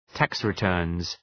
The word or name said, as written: tax returns